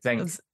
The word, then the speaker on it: Xenk: The paladin's name is X-E-N-K, like Zenik? – Zenk, I think is his name.